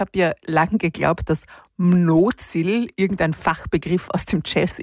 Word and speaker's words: Mnozil: Also Mnozil ist eigentlich ein tschechischer Eigenname und wird Mnozil ausgesprochen im Original und bedeutet so viel wie Fruchtbarkeit.